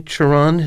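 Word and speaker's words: Cioran: I'm not someone who's known for his sunny disposition, and yet I'm very grateful to you for mentioning, do you say Chiron? His name is spelled C-I-O-R-A-N-E-M. – I've heard it. That's how I've heard it pronounced, uh-huh.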